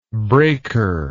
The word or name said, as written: braker